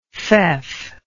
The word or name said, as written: feoff